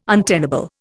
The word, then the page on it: untenable: Indian